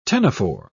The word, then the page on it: ctenophore: British English